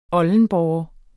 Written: oldenborre

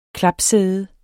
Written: klapsæde

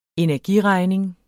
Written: energiregning

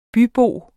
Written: bybo

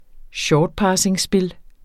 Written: SHORTPASSING

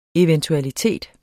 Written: eventualiteter

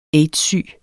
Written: aids-syg

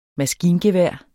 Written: maskingevær

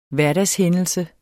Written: hverdagshændelse

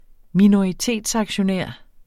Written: minoritetsaktionær

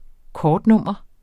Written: kortnummer

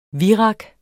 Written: virak

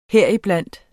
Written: HERIBLANDT

